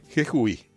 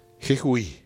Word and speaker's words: Jejuí: Jejuí. J-E-J-U-I. Jejuí.